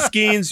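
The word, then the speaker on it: Skenes: Does his name rhyme with Fall Cleans? – Paul Skeens, you heard it here. Probably not first. Paul Skeens.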